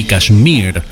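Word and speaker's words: Kashmir: Physical Graffiti es el disco que contiene Cashmere, o Cashmere, con acento en la I, Cashmere, de Led Zeppelin.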